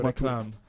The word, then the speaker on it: réclame: Comunque, in francese si dice réclamé, che vuol dire richiamare l'attenzione. Cioè, per cui ogni lingua c'ha la sua. – Io avrei detto réclame. Ho visto l'accento sulla E. – Ah, bravo, sai che c'hai ragione.